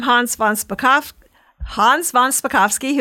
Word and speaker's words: Hans von Spakovsky: I'd like to welcome Hans von Spakovsky, who is a senior legal fellow at the Heritage Foundation's Ed Meese Center for Legal Studies.